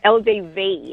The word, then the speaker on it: LDV: You drive an LDB T60. That sounds like machinery. – LDB, is that LDB? Another Chinese car, I think? – No. – You're saying it right. LDV. – Oh, LDV. LDV.